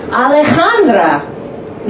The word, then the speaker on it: Alejandra: Three, Alejandra, Miss Sanders, junior year physics. Technically this is correct. The third syllable is pronounced like a Han in Hanukkah.